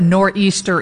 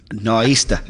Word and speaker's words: nor'easter: I don't really know what a nor'easter is. I've never lived on the East Coast. – Well, first you got to say it right. – Nor'easter.